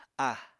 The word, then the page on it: a: Apatani